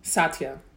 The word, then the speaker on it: Satya: Satya.